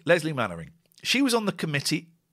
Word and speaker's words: Mainwaring: Mannering is a strange name because it's spelt Mainwaring. And we say Mannering.